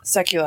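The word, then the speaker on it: Sekuła: Directed by Andrew Sekula? How would you pronounce that? – I thought I heard somebody say Sekula. – Sekula, I think, is right. Yeah, you're right.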